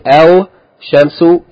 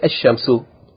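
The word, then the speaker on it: ash-shamsu (الشمس): So you don't say al shamsu, you say ash-shamsu.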